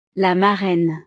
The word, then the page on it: la marraine: frances